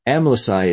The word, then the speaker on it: Amlici: Amlici is pronounced with an S as the final consonant, both in the English and Arabic translations, but that was not always the case.